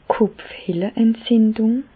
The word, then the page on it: d' Kopfhehleentzindùng: Bas Rhin; Reichshoffen